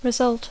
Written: result

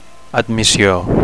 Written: admissio

